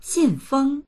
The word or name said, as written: xin4feng1